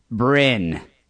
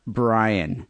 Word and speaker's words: Bryn: This one comes from Bryn. He goes, pronounced B-R-I-N, not Brian. Bryn Gunn on the forum, 40 years old, house builder in England.